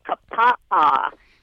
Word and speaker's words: Kapaa: And how do you pronounce the name of the town where you live in Hawaii? – Well, I'm in the lovely, sleepy town of Kapaa. – K-A-P-A-A. K-A-P-A-A, Kapaa, Hawaii.